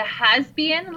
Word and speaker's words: hasbian: The has-been lesbian? – I'm sorry. – The has-been? – Has-been? – Yes. – Okay. – H-A-S-B-I-A-N.